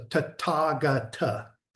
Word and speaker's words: Tathagata: And in Sanskrit in particular, TH is not pronounced th like it's we would with it's pronounced with the t. – Okay. – Yeah. So Tathagata. – Tathagata. – Tathagata. Yeah. What you really would do is there's a little bit of a hesitation between Tathagata.